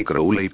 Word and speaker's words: Crowley: Crowley la llama la gran obra, pronunció magia con una A larga y Crowley con una O larga, como el pájaro.